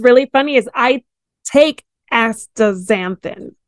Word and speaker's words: astaxanthin: And one of them was astas- astaxanthin.